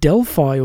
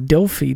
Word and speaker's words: Delphi: Spent his time as an ambassador, as a magistrate, and later in his life, he even spent some time as a priest at Delphi or Delphi, depending on how you like to pronounce it.